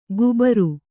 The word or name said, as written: ಗುಬಾರು